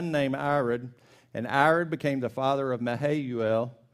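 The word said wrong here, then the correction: Mehujael: and Mahujael. That's the same guy. I just pronounced it different.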